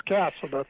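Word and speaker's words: Casada: Or just, and you don't know how to pronounce my name like the whole world. It's Cassida.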